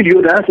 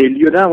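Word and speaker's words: Liaudin: Est-ce que je prononce bien, c'est Liodin? – Oui, Liodin, c'est bien ça.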